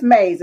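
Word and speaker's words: Mayes: I don't know, is it Mays or Ways or whatever her name is? What's her name? Let me get her name right. Chris Mays is her name.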